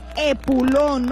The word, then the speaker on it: epulón: Y el día de hoy la palabra es epulón, no lleva H, ¿eh? Epulón es la palabra rara, sí, con acento en la O.